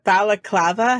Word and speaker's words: balaclava: I keep wanting to call it baklava, and I know that's a dessert. – It's a balaclava hat.